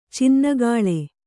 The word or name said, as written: ಚಿನ್ನಿಕೋಲು